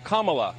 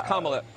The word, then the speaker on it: Kamala: Her name is pronounced comma, like the punctuation mark. Look, Kamala. Okay? – Okay. – I've heard every sort of bastardization. – So what? – That's how it is, Kamala.